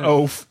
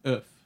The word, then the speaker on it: Oeuf: I guess not Oaf. – Yeah, Oaf is actually the one sort of mean way to pronounce it. Oof.